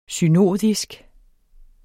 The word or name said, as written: synodisk